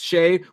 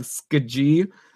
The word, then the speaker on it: Skjei: Shea, S-K-J-E-I, you said his name is pronounced Shay, which is good that you told me that, or else I would have said Skaji.